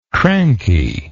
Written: crankiness

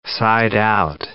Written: side-out

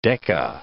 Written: Decca

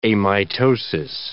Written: amitosis

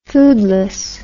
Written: foodlessness

